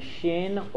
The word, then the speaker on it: Shin: This is section Shin or Sin. It's pronounced both ways.